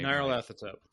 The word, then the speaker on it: Nyarlathotep: Nyarlathotep.